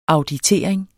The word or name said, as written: auditering